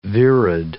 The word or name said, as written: virid